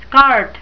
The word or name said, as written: scart